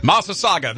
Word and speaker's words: Massasauga: How do you pronounce this? Massasauga? – Oh, yeah. – Massasauga. – Massasauga. There we go.